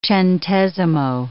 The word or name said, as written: centesimo